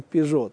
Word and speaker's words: Peugeot: или Peugeot, произносим Пежо, Так вот, как французы сегодня говорят Пари понятно, а, а мы говорим Париж.